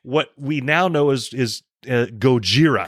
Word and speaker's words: Gojira: A couple weeks ago, I kept pronouncing it. – Gojira. – Which I think is how it really is pronounced. – I think it sounds much better. – Gojira. – Yeah. I think it's Gojira.